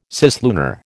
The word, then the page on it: cislunar: American English